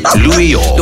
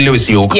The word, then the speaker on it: Lewis York: Lewis, okay, because I'm British, therefore I must pronounce things in a particular way. In French. Louis York. No, it's actually Lewis York.